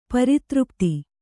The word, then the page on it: ಪರಿತೃಪ್ತೆ: pari třpte